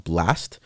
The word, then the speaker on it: BLXST: I'm gonna go ahead and certify the song "Overrated" by an artist called Blast.